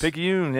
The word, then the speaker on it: Picayune: Is that how you said Picayune? – Yes. Picayune.